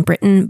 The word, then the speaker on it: Briton: I realize this would just be pronounced Captain Britain, but since you can't actually see the text, we're going to do Captain to try to accentuate the phonetic differences.